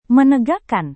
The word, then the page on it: menegakkan: Indonesian